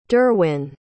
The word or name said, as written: derwin